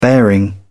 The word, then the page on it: bearing: British English